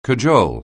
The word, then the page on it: cajole: anglais